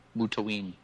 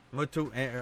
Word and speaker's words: Mutaween: It's Mutaween. Mutaween.